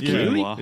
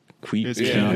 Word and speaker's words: quinoa: It is quinoa. – It's quinoa. – It's not. It's pronounced the way it doesn't look. – Okay. – Like you're pronouncing it the way it looks, but it's quinoa.